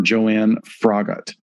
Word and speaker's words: Joanne Froggatt: Joanna Rogat. – No. – No? – No, no, no. Oh, wait. Yeah. It's not Anna. It's Joanna or Joanne Froggatt. But it's not Fogarty.